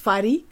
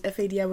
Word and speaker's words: FADÍ: So my name is FADÍ, capital letters FADÍ with an accent on the I.